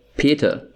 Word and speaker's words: Peter: Or you can call me just German Peter, or I'd like to call it Peter. – Peter. – Yes. That's how we pronounce it.